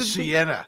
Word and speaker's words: Sienna: It's another Toyota product. It rhymes with Vienna. – The Venza. – No, come on. It rhymes with Sienna.